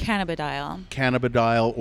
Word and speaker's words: cannabidiol: Medicinal marijuana, I think even is CBD, cannabidiol, is that how you say it? – Cannabidiol oil is that still illegals, essentially? – Yes.